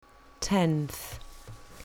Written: tenth

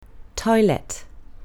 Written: toilet

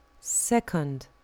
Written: second

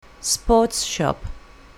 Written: sports shop

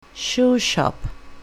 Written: shoe shop

